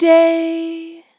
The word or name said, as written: Long Day